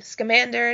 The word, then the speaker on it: Scamander: So we all know Fantastic Beasts is going to be made, and it's about Newt, is it Scamander? Is that how you say his name? – I say Scamander. – Scamander. – So do okay. – I. – Really? Okay, Scamander it is.